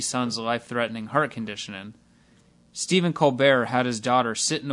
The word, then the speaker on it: Colbert: Stephen changed the pronunciation of his last name from Colbert to Colbert because an astronaut told him to.